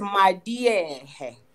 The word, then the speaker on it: Maliehe: So that L stands for D in Sesotho. – Madiehe. – So it's Madiehe.